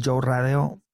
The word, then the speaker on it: Joe Radeo: Pues en mis redes sociales, tal cual como se escucha mi nombre, Joe Radeo, J-O-E, se escribe J-O-E, Radeo, R-A-D-E-O.